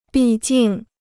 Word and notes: 毕竟: bì jìng

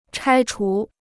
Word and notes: 拆除: chāi chú

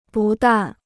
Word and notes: 不但: bù dàn

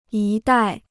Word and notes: 一代: yī dài

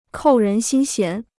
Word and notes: 扣人心弦: kòu rén xīn xián

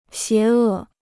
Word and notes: 邪恶: xié è

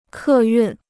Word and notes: 客运: kè yùn

